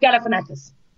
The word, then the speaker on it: Galifianakis: Zach Galifianakis.